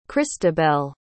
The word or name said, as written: kristabelle